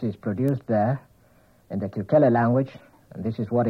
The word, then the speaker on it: Kukele: Kukele, that's spelled K-U-K-E-L-E, Kukele.